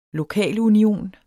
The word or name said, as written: lokalunion